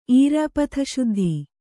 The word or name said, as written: ಈರ್ಯಾಪಥಶುದ್ಧಿ